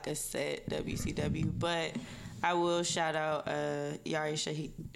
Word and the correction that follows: Yara: Is it Shahidi? – Yara Shahidi. – Okay, I just want to make sure I said it right.